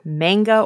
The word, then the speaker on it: manga: Is it manga or manga? – Manga. – I used to always say manga, but yeah, I think you corrected me once. Manga.